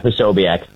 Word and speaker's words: Posobiec: Yeah, Posobiec.